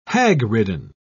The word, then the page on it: hagridden: British English